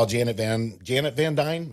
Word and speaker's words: Janet Van Dyne: Yeah, we get to see this whole world within a world because, you know, last time we saw Janet Van Dyne, am I saying that right? – Yes.